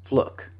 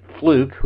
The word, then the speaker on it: Fluke: Fluck, actually. She pronounces it Fluck. Former Speaker Pelosi pronounces it Fluke, which is how it's spelled, but Fluck is how she said it, so we're going to go with that.